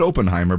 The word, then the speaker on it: Oppenheimer: Now, I said Oppenheimer, but you said Oppenheimer, which I'm sure is the correct way to say it. Oppen, because it has two P's, and so yeah.